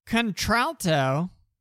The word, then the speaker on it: contralto: Contralto, pronounced contralto, is a type of classical female singing voice whose vocal range is the lowest female voice type.